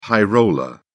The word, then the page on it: high roller: British English